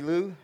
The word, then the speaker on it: Liu: All right, so my name is Davey Liu, and I grew up in the South, so they think, you know, Davey Liu and Mary Lou, so it's spelled actually L-I-U, so, but it just fits just fine.